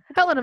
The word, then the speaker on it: Helena: even though you're located all the way in Helena. Am I saying that right? Montana? – Helena, Montana, but that works.